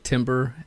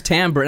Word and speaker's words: timbre: There's a slight possibility we might be pronouncing the word timber, as in the musical term, incorrectly.